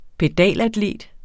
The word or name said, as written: pedalatlet